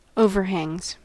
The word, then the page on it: overhangs: American English